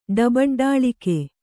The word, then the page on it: ಡಬಡ್ಢಾಳಿಕೆ: ḍabaḍḍhāḷike